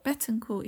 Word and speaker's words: Betancourt: Betancourt, yeah.